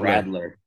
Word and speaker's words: Radler: It is spelled Radler. – Rattler.